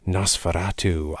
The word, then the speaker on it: NOS4A2: If you say that phonetically, it sounds like Nosferatu, i.e. vampire.